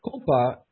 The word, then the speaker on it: kompa: Now, kompa is a word that we use normally to mean meditation. The spelling's a little bit different here. It's pronounced kompa, and it is related to the word for meditation.